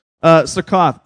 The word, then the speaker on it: Succoth: So he said to the men of Succoth," got to pronounce that O, although it sounds like a different word, "Succoth,